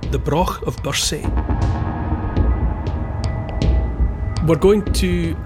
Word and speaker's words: Brough: And the Broch of Bursay, it's spelt B-R-O-U-G-H, pronounced Broch.